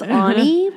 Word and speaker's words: Aune: It's Ani, but it's spelled A-U-N-E and everyone fucks it up.